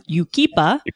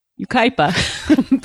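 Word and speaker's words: Yucaipa: Ryan, Darren from Yukipa. – Yukaipa.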